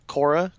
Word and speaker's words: Korra: They said that they're going to do a Legend of Korra comic.